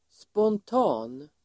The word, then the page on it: spontan: [spånt'a:n]